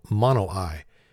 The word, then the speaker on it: Monowi: Okay, our first story comes from a town in Boyd County, Nebraska called Monowi. M O N O W I. Don't pronounce the W. Come on, people.